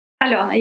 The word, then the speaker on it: Alyona: It's Alena Zhuk. – Alena. – But you're quite close to it. Okay, I'll try pronouncing it right. Alena? – Alena, yes.